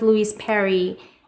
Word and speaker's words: Louise Perry: I think it was a conversation you had with Louise Perry. Did I get her name right? – Yes.